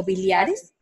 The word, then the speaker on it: biliares: Y es también importante que las personas que estén embarazadas con ah, cálculos bilares, bilares si se dice así, o biliares, Ajá, sí, son raro.